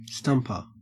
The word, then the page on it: stumper: Australian English